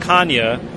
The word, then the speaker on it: Canha: I have to ask a couple weeks ago, there was a debate, Mark Canha going on Sunday Night Baseball and saying his name was actually pronounced Kanya, being a fourth generation Portuguese.